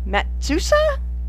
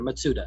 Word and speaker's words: Matsuda: Circumstances force Wesley to the emergency room and to face with the beguiling Dr. Matsuda? Pronounce it for me. – Matsuda. – Matsuda. Oh, the S is silent. See? – No. This is it. Matsuda.